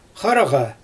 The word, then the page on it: xaraɣa: Xyzyl